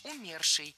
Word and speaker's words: умерший: Умерший — неверно. Ударение в этом слове должно падать на корень слова «умерший». Это, пожалуй, самое проблемное причастие с точки зрения ударения. В нем очень часто делают ошибку. Его надо просто запомнить — «умерший».